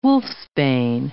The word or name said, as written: wolfsbane